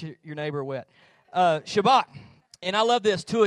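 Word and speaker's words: Shabach: Shabak. You kind of, if you want to say it right, you got to do the like you got a popcorn kernel stuck in the back of your throat.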